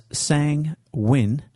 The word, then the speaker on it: sanguine: Sanguine, which is pronounced as sang-win, as in sang plus win, it can be used as the crowd was hopeful that the team would win, so they all cheerfully sang win, win, win.